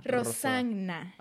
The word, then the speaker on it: Rossana: Mi nombre tiene dos S y dos N. – Ah, yo me aseguré de pronunciar. Dije se pronuncia Rosagna.